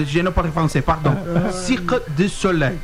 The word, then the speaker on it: Cirque du Soleil: Ensina ele a falar Cirque du Soleil aí, que acho que ele falou errado. Por favor, como é que... qual que é a pronúncia correta em francês, você que esteve lá durante muito tempo?